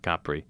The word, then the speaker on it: Capri: A caprese is a salad that originates in the island of Capri, C-A-P-R-I. So, correctly pronounced Capri by many people,